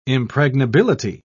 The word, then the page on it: impregnability: British English